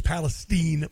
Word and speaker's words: Palestine: It's Palestine, by the way. That's how they pronounce it.